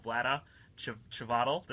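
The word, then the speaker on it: Vlaada Chvátil: Vlada Chvadal. Did I do that right? – Yeah, I think that's almost exactly right.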